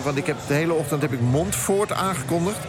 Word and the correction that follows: Montfort: Ik werd daar terecht door gecorrigeerd in de NPO Radio 1. Het is Montfort. – Oh, klemtoontechnisch gesproken. – Montfort, ja. Ik heb Montfort. Wist u dat, meneer, dat het Montfort is en niet Montfort?